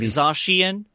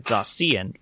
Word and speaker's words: Zacian: Like Zacian, which I've been pronouncing wrong. I've been pronouncing it Zacian.